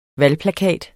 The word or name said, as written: valgplakat